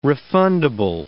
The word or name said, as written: refundable